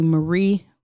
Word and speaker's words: Marier: It's actually Marie with the initial R.